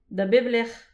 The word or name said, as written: da bep lech